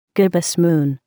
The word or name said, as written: gibbous moon